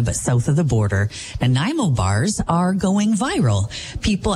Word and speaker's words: Nanaimo: fair to say most of us on the West Coast know how to pronounce the name Nanaimo, a city on Vancouver Island, the destination for the Horseshoe Bay Ferry over to Departure Bay, Nanaimo.